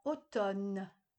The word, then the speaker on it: automne: On ne prononce pas le M dans automne.